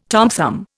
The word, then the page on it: Tom Thumb: Indian